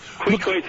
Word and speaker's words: Queequeg: Queequeg is how you pronounce that bizarre symbol.